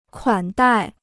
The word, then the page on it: 款待: kuǎn dài